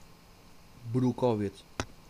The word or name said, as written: brukowiec